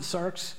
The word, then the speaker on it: sarx: The Greek word there, you pronounce it sarx, is how you pronounce it, but it really is one who is or becomes a physical being, a living being with flesh.